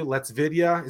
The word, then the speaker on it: LetsVidya: Let's Vidya. Is that how I say it? I mean, that's how you say it. That is right.